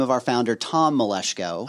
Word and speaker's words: Maleshko: you actually said it correctly, too. So props. Because we, we get the whole gamut Maleshko, Maleshkio. Yeah, also.